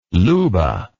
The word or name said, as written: Luba